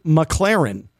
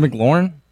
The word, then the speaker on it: McLaurin: Is it McLaurin? – No, it's Terry McLaurin. It's pronounced McLaurin. I am a fan. Got bad news. Got bad news. Did some research during the break. It is indeed Terry McLaurin, not Terry McLaurin.